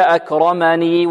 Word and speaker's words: أكرمن: أكرما، إذن هذه كلمة أكرما فيها ياء زائدة تثبت في الوصل وتحذف ماذا؟ في الوقف. إذا وقفنا عليها هكذا: فيقول ربي أكرما، وإذا وصلنا هكذا: فيقول ربي أكرمني وأما أكرمني وأما، بارك الله فيكم.